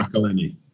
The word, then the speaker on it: McElhenney: Ryan Reynolds and uh, I can never pronounce his surname, Rock, Rob Mc, McElhenney. Is that right? McElhenney. Yeah, McElhenney. That's the one.